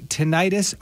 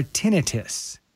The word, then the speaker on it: tinnitus: But first, the question is, do we say tinnitus or tinnitus? – See, I'm still wondering about that. So I've heard both tinnitus and tinnitus, and I'm never quite sure what's actually the right pronunciation. So but both works.